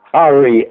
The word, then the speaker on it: Ari: Ari, A-R-I. It's like Harry without an H, Ari.